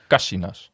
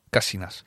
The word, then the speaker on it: Casinas: Het is Casinas. Casinas. – Neemt graag... Eerste lettergreep.